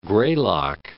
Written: Greylock, Mount

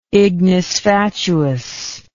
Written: ignis fatuus

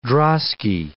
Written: drosky

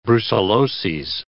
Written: brucelloses

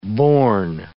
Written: borne

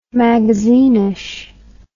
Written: magazinish

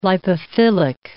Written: lipophilic

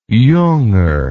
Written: junger

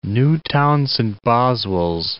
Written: Newtown Saint Boswells

